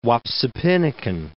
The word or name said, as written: Wapsipinicon